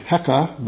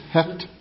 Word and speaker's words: Hect: Hekt, H-E-C-T is the name. In fact, some pronounce it Heka, but it's Hekt, H-E-C-T.